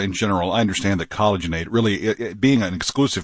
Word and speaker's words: collagenate: So there's an A-T-E on the end, just like rejuvenate, but it's collagenate.